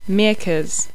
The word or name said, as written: Meercas